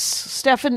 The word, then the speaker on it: Stefan: He comes from very, very French people. Mm-hmm. And so they went with Stefan, and so be it.